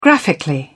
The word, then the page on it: graphically: British English